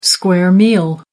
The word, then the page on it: square meal: American English